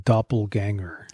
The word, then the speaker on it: doppelganger: It's pronounced doppelganger.